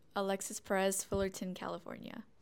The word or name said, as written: Alexis Perez